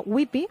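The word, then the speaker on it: Ypi: L'autora, espero dir-ho bé, es diu Lea Whippy, perquè es pronuncia Whippy, ha dit Anagrama, i la traducció és de Cecilia Ceriani.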